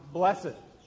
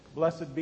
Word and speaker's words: blessed: And it has to do with this word blessed, in spite of the fact that most of you would pronounce it blessed. In fact, the one song we sang this morning, we had to actually sing it incorrectly to get it to rhyme and to fit the meter. Blessed be, like, blessed be the name of the Lord when in reality, believe it or not, the word is blessed. One syllable.